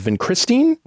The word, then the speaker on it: Vincristine: This time of a drug called Vincristine.